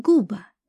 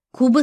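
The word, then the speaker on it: Quba: На азербайджанском этот город назывался с более звонким «ка» и скорее произносился как «губа», но в русском языке название города традиционно начиналось с буквы «к». Куба стала известна уже после распада Советского Союза, еще и благодаря Красной Слободе, в которой компактно проживали горские евреи.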